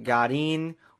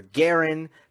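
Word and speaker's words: Garín: And then finally, in South America on the clay, it is Christian Garin, or Garin, depending on if you want to say it correctly or if you want to sound like an American.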